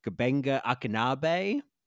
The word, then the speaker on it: Gbenga Akinnagbe: And, you know, we didn't say much about Jimmy, the nurse played by, I'm going to butcher this name, but I think it's Gbenga Akinabe. – Yeah, that's how I would close. Say it, yes.